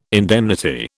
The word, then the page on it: indemnity: British English